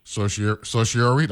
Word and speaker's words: certiorari: When the government sought certiorari, that's how you pronounce it.